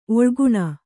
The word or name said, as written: ಒಳಗಾಣ್